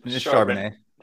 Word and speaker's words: Charbonnet: Then the next three is Zach Charbonnet, and I hope I'm saying that right. Am I saying that right? – No, it's just Charbonnet. Sounds like a nice fine wine.